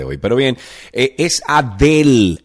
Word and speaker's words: Adele: Oye, hey, es Adele, y no eso ha dado la vuelta al mundo.